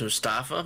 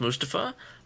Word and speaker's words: Mustafa: I always thought it was Mustafa, my bad, but it's Mustafa.